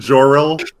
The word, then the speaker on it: Jor-El: You're gonna tell me that you're gonna pronounce it Hack Man, but you're gonna call him Kal-El and Jor-El.